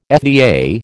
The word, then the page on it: FDA: American English